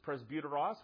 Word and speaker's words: presbyteros: You don't have to be able to read the Greek there yourself, because I'll tell you what the Greek says so that you will know how to pronounce it. But it's presbyteros.